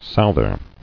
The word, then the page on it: Souther: south·er